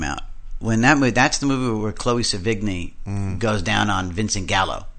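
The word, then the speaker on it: Chloë Sevigny: Chloe Sevigny.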